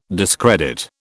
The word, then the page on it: discredit: British English